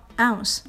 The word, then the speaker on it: ounce: 哎，这个 OZ 是容量单位盎司的缩写，读作 ounce。